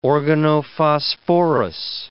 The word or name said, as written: organophosphorous